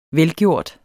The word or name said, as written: velgjort